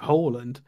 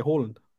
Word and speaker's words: Haaland: I think it was always known as Haaland, to be fair. – Yeah, that's what I say, Haaland. Haaland, that's not how it's pronounced. – No, it's basically a Dutch hooker and then land.